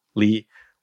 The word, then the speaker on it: Lee: Lee, one syllable, three letters.